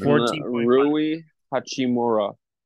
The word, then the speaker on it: Rui Hachimura: Rui Hachimura.